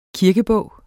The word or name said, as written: kirkebog